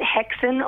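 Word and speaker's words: Häxan: Yeah, and your new single, is it pronounced Haxan? – Yeah, absolutely. You can pronounce it Hexen or Haxen. It's Scandinavian for witches.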